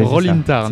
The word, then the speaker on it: Roll in Tarn: Alors c'est bon, je l'ai bien prononcé. Hein. – Tout à fait. – Roll in Tarn. – C'est bien Roll in Tarn, on roule dans le Tarn.